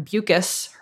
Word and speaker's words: Bucus: We have a hot tip coming in live in real time from Mark, who says, according to Lauren Bucus, her name rhymes with mucus.